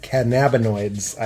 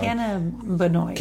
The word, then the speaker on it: cannabinoids: They're chock full of premium CBD and a full spectrum of active cannabinoids. I always say that wrong. – Cannabinoids. – Cannabinoids. – Oh, really? – Cannabinoids. – I always said cannabinoids.